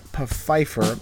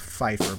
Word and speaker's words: Pfeiffer: from a blogger by the name of Eric Pfeiffer. I guess it's Pfeiffer, but I always go Pfeiffer because it, you know, the silent P.